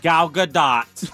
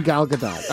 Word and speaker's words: Gadot: And did we look up Gal Gadot? – Yep. – And? – Gal Gadot. – All right. So she pronounces her name Gal Gadot.